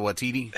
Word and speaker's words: Waititi: Waititi? – Yeah, Taika Waititi.